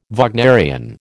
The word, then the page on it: Wagnerian: American English